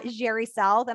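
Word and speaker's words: Jheri South: Well, I can be found at Jerry South, and I have a unique spelling. So it's JHERISOUTH.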